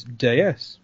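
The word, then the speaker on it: Déesse: But did you know that the French pronunciation of DS is Deus, which means goddess?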